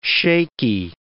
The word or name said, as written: shaky